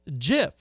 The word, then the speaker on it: GIF: The inventor of the widely used web illustration declared it should be pronounced GIF. Like the brand of peanut butter, rather than with a hard G sound.